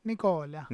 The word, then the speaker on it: Nicola: ¿Nicola se pronuncia en, italiano Nicola? Nicola.